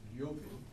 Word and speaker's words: Levin: By the way, Tolstoy's nickname was Yovin, and that's why L-E-V-I-N is pronounced Yovin. Lev is Lev, but when it becomes a diminutive, little Lev, Yovina, then the E changes to a Yo in Russian. And so that's why it's there is some significance in that vowel that's spelled one way but pronounced another.